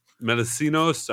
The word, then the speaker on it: Melissinos: Melis, Melis, Melissinos.